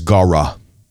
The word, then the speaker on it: gara: The word rooster in Aramaic is gara. Gara.